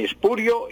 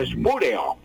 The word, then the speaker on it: espurio: Con S, no con X, espurio. Y espurio y no espúreo. Y pues sin tilde, en ninguna parte lleva tilde, Laura.